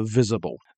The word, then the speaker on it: visible: 所以就变成了 visible。 Visible，那个 S 是 Z 的发音的哈。